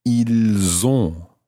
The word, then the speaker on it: ils ont: So that's I-L-S. And the verb itself is O-N-T. So what you end up with is pronouncing the S of the il in that one because it's following, or it's followed by a vowel.